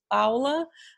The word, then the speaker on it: Paula: It's like here, when I say my name, I say in Portuguese, it's Paula. But I've stopped doing that, because people always go, what? I said Paula.